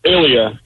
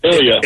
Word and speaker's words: Ilya: Did I say your name right? – Ilya. Ilya. – Ilya. – Like when you're sick, you're ill.